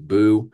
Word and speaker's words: Buh: B-U-H, just pronounced Boo.